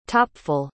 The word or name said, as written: topful